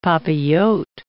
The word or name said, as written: papillote